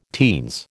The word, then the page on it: teens: American English